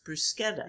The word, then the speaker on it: bruschetta: It's pronounced bruschetta. The people in the U.S., you know, especially at Applebee's, yeah. – Pronounce it bruschetta.